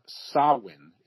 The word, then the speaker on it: Samhain: Oh, we just got Samhain is the actual pronunciation.